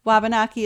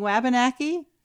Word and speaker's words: Wabanaki: Eileen sends an email saying we are not pronouncing Wabanaki as the natives pronounce it. She says we need to be saying wah as in a baby's cry.